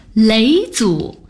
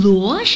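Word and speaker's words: 嫘祖: 雷祖。你不要以为我不会读那个字哦，左边女字旁，右边是疲累的累，这个字读作雷。哼，你八成以为我会读成罗是吧？田螺的螺是虫字旁，雷祖的雷是女字旁，不错嘛。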